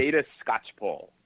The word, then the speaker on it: Theda Skocpol: I'll help you out here. – Thank you. – Theda Skocpol. Theda Skocpol, who's a political sociologist.